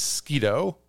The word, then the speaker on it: Sketoe: His name is Sketoe, like Mosquito.